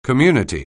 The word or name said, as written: community